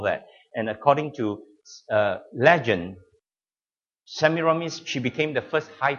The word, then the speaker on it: Semiramis: Semiramis. You've got to pronounce it correctly, all right? Semiramis.